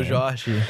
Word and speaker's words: Seu Jorge: Seu Jorge, who plays yes.